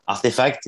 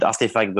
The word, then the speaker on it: RTFKT: So Artifact, and also the name is pronounced Artifact, even if we wrote it RTFKT. It's because when we started the company, we wanted to call it Artifact, but we couldn't find the domain name. It was already taken. So we used a special way of writing it with just weird letters. So it's cool because today it acts a bit like a secret code people need to know, you know, to really know how to pronounce the brand name.